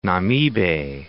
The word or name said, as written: Namibe